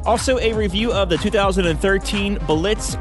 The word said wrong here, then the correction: Berlitz: how do you say that? – It's Berlitz. – Berlitz, yes.